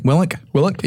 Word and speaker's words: Willink: Willink? – Yeah, Jocko Willink.